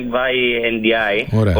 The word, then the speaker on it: Mbaye Ndiaye: Ο Κώστας Θάνο θα σου πει Mbaye Ndiaye, όπως το λέει ο παίκτης στο site της EuroLeague, όταν τον βάλανε να προφέρει το όνομά του.